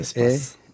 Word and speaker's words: Espas: Konuğumuz, editör ve Acımasız Aydınlık adlı kitabın e, bu Espas yayınların böyle mi okunuyor? – Espas.